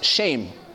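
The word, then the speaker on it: shame (שם): The Hebrew word is this word, Shem. It's actually pronounced shame.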